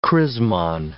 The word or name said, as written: chrismon